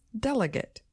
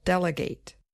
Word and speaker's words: delegate: So the noun form just has stress on the first syllable, delegate. But the verb form also has a little stress on the last syllable, delegate.